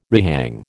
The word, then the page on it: rehang: American English